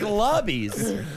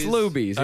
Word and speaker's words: Luby's: Looks like Lubby's. – Or... – No, it's pronounced Luby's. Yeah.